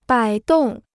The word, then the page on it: 摆动: bǎi dòng